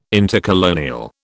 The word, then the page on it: intercolonial: British English